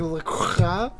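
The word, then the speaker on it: LaCroix: I'm drinking Le Croix. – Le Croix? – That's how you pronounce it.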